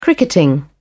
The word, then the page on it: cricketing: British English